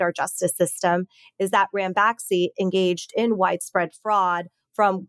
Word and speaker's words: Ranbaxy: So probably the most high profile case of this was the story about Ranbaxy. Am I pronouncing that right? – Yes, you are.